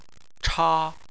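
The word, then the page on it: cha: tone 1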